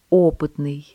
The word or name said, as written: опытный